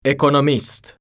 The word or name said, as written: ekonomist